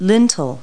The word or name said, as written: lintel